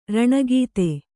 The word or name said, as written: ರಣಗೀತೆ